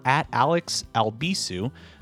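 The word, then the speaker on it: Alex Albisu: If you'd like to follow me on social media, you can do so by just searching for @AlexAlbisu. Last name is spelled A-L-B as in boy, I-S as in Sam, U.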